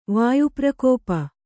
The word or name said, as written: ವಾಯುಪ್ರಕೋಪ